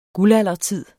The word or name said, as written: guldaldertid